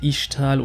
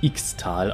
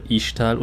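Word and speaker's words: Ixtal: Erstmal, ja, es heißt wirklich Ichtal und nicht Xtal, auch wenn es so geschrieben wird. Man spricht X nicht immer so aus. Es gibt verschiedene Sprachen, in denen dieser Laut anders ausgesprochen wird.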